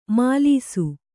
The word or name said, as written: ಮೆಲಿಸು